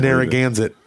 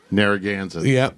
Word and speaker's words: Narragansett: I can't Narragansett. – Narragansett.